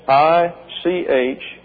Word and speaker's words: ichneumon: That's ichneumon. The O here, the omega, is a long O in the Greek and pronounced as a long O in the English.